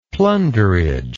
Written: plunderage

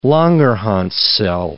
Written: Langerhans cell

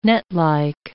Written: netlike

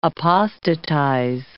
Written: apostatize

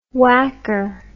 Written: wacker

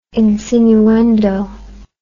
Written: insinuendo

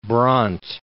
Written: Brandt

